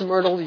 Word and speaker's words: myrtle: What is myrtle used for in the Bible? I won't discuss that one, Dave. Thank you for enlightening us that it does rhyme with turtle.